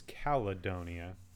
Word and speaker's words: Caledonia: So, as indicated by the name, I also been calling this Caledonia. I'm pretty sure it is Caledonia. With an extra syllable in there.